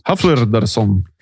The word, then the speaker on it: Hallfridason: Og denne presten har navnet Einar Hallfridason. Hvordan har du uttalt det der? – Hallfridason. – Hallfridason.